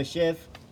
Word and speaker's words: shiv: And then there's a sheave. Shiv. Sheave. Shiv. Am I saying it wrong? – Oh, yeah. We have a shiv.